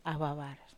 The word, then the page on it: awawar: Wamesa